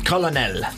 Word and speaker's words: colonel: Especially because a colonel isn't how it's spelt. You don't say it like that. – Colonel.